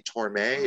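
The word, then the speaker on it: Tormé: And then, like you pointed out, Greg, Randy dies first to get Bernie Torme.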